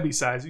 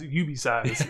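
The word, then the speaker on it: UBeesize: Not IB Size, UB Size. Yeah. UB Size is U, the letter, B-E-E, size, like you get a small size of something.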